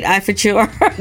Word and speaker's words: Ifeachor: Did I say it right, Ifeachor? – You did.